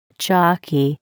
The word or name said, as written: jockey